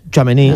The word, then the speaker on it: Tchouaméni: ¿Cómo se pronuncia? ¿Tchouaméni? – Tchouaméni, sí. Tchouaméni.